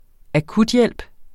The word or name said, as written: akuthjælp